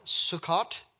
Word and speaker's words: Sukkot: The tabernacle of the booths, known as Sukkot, S-U-K-K-O-T,